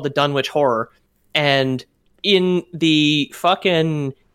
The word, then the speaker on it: Dunwich: So it's spelled Dunwich. Dunwich is a real town in Scotland, pronounced Dunwich. But it's set in a town in New England, so it may have been pronounced Dunwich. Lovecraft never specified which way it was supposed to be pronounced.